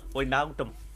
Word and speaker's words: Wijnaldum: Wijnaldum.